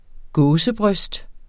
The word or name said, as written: gåsebryst